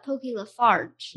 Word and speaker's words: LaFarge: And you sent me, Pokey LaFrange, is that how you say his name? – Pokey LaFarge. – LaFarge, yeah.